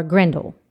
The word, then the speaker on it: Grendel: So what they did was they did some projects where you had to create, you know, Beowulf or Grendelin.